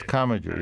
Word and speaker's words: Commager: So the historian, and there are a couple of them here, I want to talk about Henry Steele Commager, if I'm pronouncing it. – Yes, yes, Commager, yes.